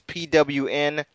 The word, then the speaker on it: pwn: Pwn Team, it's PWN. That's pronounced Pwn for those of you who do not know internet lingo.